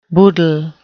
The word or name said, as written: boodle